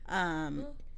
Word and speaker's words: Methot: I hope I'm saying it right. Methot. – Sounds right.